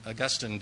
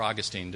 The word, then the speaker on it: Augustine: It's when Augustine did his thing, or Augustine, depending how you say his name.